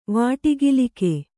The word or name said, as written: ವಾಟಿಗಿಲಿಕೆ